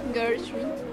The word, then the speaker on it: Gershwin: Gershwin. ¿Cómo? Gershwin.